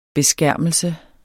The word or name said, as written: beskærmelse